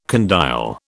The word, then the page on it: condyle: British English